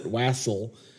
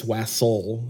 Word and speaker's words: Wassail: And I also looked up the name of this to make sure I pronounce it correctly, because you could pronounce it Wassel, but it's Wassel. Like, there's a UHL pronunciation with it.